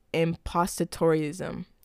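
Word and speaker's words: impostorism: Basically, impostatorism. That's how you say it.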